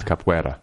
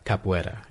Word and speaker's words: capoeira: I was wondering how to pronounce that. – Yeah. – It's capoeira. – Capoeira. – Okay.